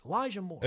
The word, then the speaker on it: Elijah Moore: Is it Elijah Moore? Why does that not sound right? Elijah Moore. – It's Elijah Moore.